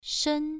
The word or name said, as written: shen1